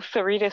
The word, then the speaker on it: Syreeta: Syreeta C Fashion.